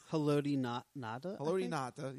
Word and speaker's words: Haloti Ngata: Haloti Ngata, yes, there we go.